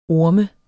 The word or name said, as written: orm